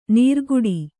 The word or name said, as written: ನೀರ್ಕುಡಿ